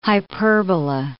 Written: hyperbola

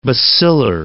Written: bacillar

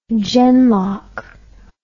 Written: genlock

